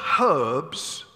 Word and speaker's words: herbs: And the bitter herbs, the bitter herbs, by the way, why don't you people pronounce the H? herbs? Herbs? That's horrible. that was silly, wasn't it, really?